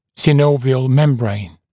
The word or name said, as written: synovial membrane